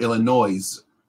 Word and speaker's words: Illinois: It's spelled I-L-L-I-N-O-I-S. – Okay. – So is it Illinois or Illinois? – Illinois. – There you go. Illinois. Yeah. Don't pronounce the S.